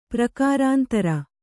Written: ಪ್ರಕಾರಾಂತರ

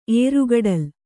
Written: ಏರುಗಡಲ್

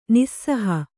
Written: ನಿಃಸಹ

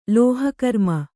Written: ಲೋಹಕರ್ಮ

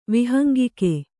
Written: ವಿಹಂಗಿಕೆ

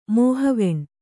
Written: ಮೋಹಿವೆಣ್ಣು

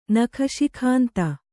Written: ನಖಶಿಖಾಂತ

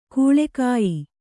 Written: ಕೀಳುಗಯ್ಯು

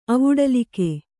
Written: ಅವುಡಲಿಕೆ

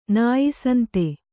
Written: ನಾಯಿಸಂತೆ

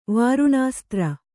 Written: ವರುಣಾಸ್ತ್ರ

